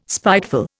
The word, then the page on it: spiteful: Indian